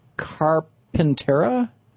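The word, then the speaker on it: Carpinteria: Greg in Carpentera, California. Am I saying that right? – That's right. Yes.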